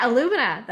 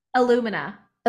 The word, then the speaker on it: Illumina: Illumina. – Illumina. Like kind of like aluminum. Okay. Illumina. – Yeah. Like aluminum. So or like illuminate, you know, like illuminate.